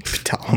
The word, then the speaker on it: Ptolemies: The P is silent. Ptolemies.